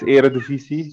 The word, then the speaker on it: Eredivisie: Eredivisie, right? How is that pronunciation, brother? – That's great. It's Eredivisie. So your pronunciation is almost the same.